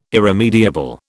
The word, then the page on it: irremediable: British English